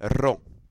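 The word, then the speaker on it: ro (ρ): You have to pronounce it with the tip of your tongue, not with the throat, although to some men and women, ro, used as ro, sounds sexy. So you say ro.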